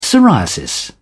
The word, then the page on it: psoriasis: British English